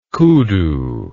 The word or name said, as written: koodoo